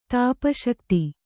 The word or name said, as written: ತಾಪಶಕ್ತಿ